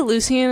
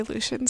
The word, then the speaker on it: Lucien: I could see her with Lucien, however you say his name. – I say Lucien, and I know some people say Lucien, so it's I think he could provide a lot of safety for her.